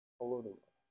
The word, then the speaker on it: Holodomor: Chuck asked me to talk about the, how do you say it, the Holodomor? Holodomor.